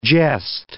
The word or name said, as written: gest